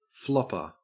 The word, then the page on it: flopper: Australian English